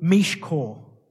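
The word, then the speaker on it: mishchah: In the Hebrew, it's the word mishkor. Mishkor. It's spelt M-I-S-H-C-H-A-H. M-I-S-H-C-H-A-H.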